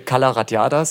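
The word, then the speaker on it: Cala Ratjada: Es müsste Calaratiada sein. – So einfach spricht man das, Calaratiada? – Ja, okay.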